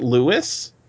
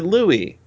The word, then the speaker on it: Louis: and I also didn't know that I was thinking, is it Louis or is it Louis? But Louis is much better. I mean, the audiobook makes it clear, right? – Mm-hmm. – Yeah. At least by collapsing the choice. But Louis can be spelled L-E-W-I-S.